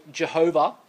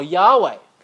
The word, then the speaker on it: YHWH: In Hebrew, it's YHWH, which we pronounce either Jehovah or Yahweh.